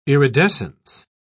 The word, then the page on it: iridescence: British English